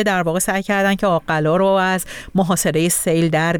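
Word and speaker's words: آق‌قلا: البته فکر میکنم تلفظ این شهر آقلا هست از سابقه قطع من اون رو تلفظ کردم، در واقع یکمی دچار اشتباه شدم.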